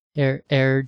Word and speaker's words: Heir: They called this article Air Jordan, but it's spelled H-E-I-R, Air Jordan.